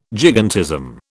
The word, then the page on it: gigantism: British English